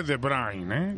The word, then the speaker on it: De Bruyne: Que me moleste, se dice De Bruyne, ¿eh? Que el otro día me lo enseñaron aquí ya los belgas.